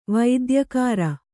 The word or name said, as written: ವೈದ್ಯಕಾರ